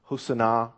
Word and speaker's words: Hosanna: If we want to get technical, I think the correct pronunciation is Hosanna.